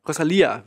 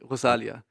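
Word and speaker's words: Rosalía: Rosalia wird sie ausgesprochen, nicht Rosalia. Ros ähm, Rosalia.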